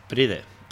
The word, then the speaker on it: Pride: Os dejamos, en todo caso, esa recomendación de la peli Proud para... – Pride. – Proud. ¿Cómo se pronuncia eso? – Pride, perdón, sí, eso. Joder. Proud es otra cosa, sí.